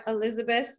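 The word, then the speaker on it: Elisabeth: Yes, they can find me on IG at Elizabeth, spelled with an S. I know my name is spelled a little bit differently. A lot of people get it wrong and spell it with a Z, but it's ElisabethI, as in the letter I, and then Hoekstra, my last name, Hoekstra.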